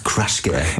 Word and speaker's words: Kruschke: Bin auch sehr stolz auf mich, dass ich diesen Namen richtig ausgesprochen habe, weil es gibt ja häufig Leute, die das so schreiben, dann so, nein, das heißt Kruschke oder nee, so.